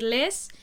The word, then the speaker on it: Les: Porque Leslie es Leslie, se escribe solo con una S, pero yo me autodenominé Les, como Les menos en inglés, pero no tiene nada que ver, o sea, eso no fue coincidencia.